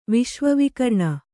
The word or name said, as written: ವಿಶ್ವವಿಕರ್ಣ